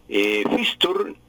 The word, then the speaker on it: fixture: Fixture, se pronuncia sin la E final,